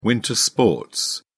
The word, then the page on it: winter sports: British English